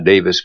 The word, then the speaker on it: Davies: Davies is her last, D-A-V-I-E-S, not Davis, but Davies. – I'll bet it's pronounced Davis, because the county that's spelled like that is pronounced Davis.